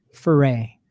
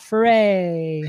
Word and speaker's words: Ferre: A lot of people default to Ferre. Some people call me Ferre. That's what I grew up as. And then I pivoted to Ferre. So I always say it rhymes with hooray, hip hop, Ferre, ho, hey.